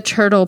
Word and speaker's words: TRTL: Number two, the turtle pillow. T-R-T-L, but it's pronounced turtle.